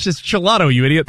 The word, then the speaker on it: gelato: And I thought it was like a soft G, like you might pronounce a J in Spanish. So I was like, I'll have the gelato.